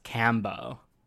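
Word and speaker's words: Kambo: No, what's Kembo? Kembo is frog venom and people take it? – Yeah. It's actually pronounced Cambo.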